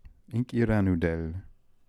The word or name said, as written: Inkiranoodel